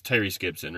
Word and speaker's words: Tyrese Gibson: It's Tyrese Gibson, right? – Yep.